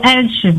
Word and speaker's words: Elche: E-L-C-H-E. Elche. C'est Elche, mais il faut dire Elche. Je ne comprends pas qu'on soit en train de dire. Alors, on est obligé de dire prononciation Elche.